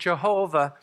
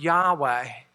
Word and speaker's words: Yahweh: And so some have added a J sound and come up with Jehovah. Most scholars believe it's pronounced Yahweh.